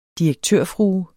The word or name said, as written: direktørfrue